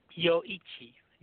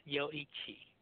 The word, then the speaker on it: Yoichi: It's Yoichi. Yoichi.